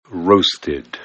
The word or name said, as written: roasted